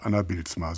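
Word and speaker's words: Anner Bylsma: Anna Bilzma, so wird der Cellist ausgesprochen.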